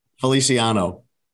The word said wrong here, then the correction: Felicio: That's not his name. It's Cristiano Felicio.